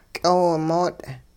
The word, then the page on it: ky'oowimoot': hupa